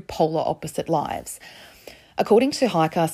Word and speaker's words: Heike: Now, before anyone says anything, in case you're German or speak German, I've looked up how to say this. 9 out of 10 people say Hiker, and that one person out of 10 says Heike. So I'm going to say Hiker. It's H-E-I-K-E.